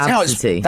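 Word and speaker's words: Abcde: That's Absity.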